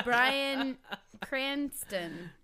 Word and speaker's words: Bryan Cranston: That's Bryan Cranston.